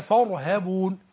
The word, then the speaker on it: فارهبون: وإياي فارهبون ما تقولش لا تتنطع، لا تفخم الهاء، لا تنطق الهاء من مخرج الغين وتظلم نفسك وأنت. نعم. تقرأ كتاب الله تبارك وتعالى. – نعم. – تقول إيه وإياي فارهبون هذا أمر.